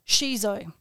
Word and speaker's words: Sijo: It's a poetry called Sijo. Shizo, and it's Korean poetry. It is spelt S-I-J-O.